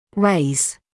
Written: raise

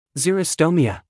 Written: xerostomia

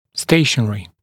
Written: stationary

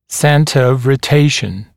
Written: center of rotation CRo

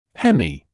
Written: hemi-

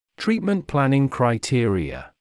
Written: treatment planning criteria

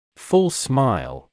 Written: full smile

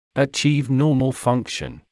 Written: achieve normal function

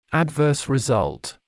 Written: adverse result

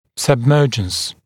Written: submergence

